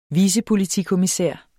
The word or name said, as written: vicepolitikommissær